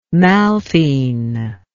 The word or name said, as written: malthene